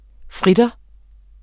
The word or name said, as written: fritter